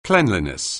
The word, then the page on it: cleanliness: anglais